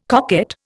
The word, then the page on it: coquette: Indian